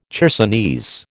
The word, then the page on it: chersonese: American English